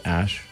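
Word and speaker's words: Æ: Letter X, the AE is like pronounced Ash.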